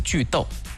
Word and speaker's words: 句读: 其实不对，应该是句逗，这个逗通逗号的逗，句逗简单来讲就是断句的意思。为什么要说这两个字呢？因为我们推荐的 APP 跟这两个字有关。先来说这两个字是什么意思，句逗，逗是读书的读，句逗只不过在这个地方它的发音是逗。